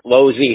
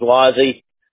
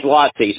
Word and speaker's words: Losey: It doesn't matter. Yeah, we say Losey here. The right way to say it is Losey. And, okay. – You know, the Italian way is Lossy, so.